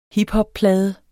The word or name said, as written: hiphopplade